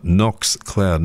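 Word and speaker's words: Nox Cloud: Knox Cloud, N-O-X is how you spell it, Knox Cloud, no K on the front, for all your cyber security, etc., etc., worth checking out.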